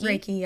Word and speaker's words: Reiki: Well, and you're, and I'm gonna mispronounce this because I always do, Reiki. – Oh, Reiki. – Reiki, yeah.